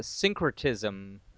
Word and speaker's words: syncretism: This is syncretism, is how it's pronounced.